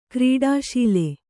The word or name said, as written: ಕ್ರೀಡಾಶೀಲೆ